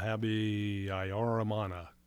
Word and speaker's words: Habyarimana: Now, the last name, I'm going to spell it. – Okay. – Habyarimana. – Juvenal Habyarimana. – You did much better than I did.